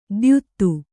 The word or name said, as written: ದ್ಯುತ್ತು